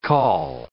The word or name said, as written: col